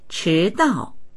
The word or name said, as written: chi2dao4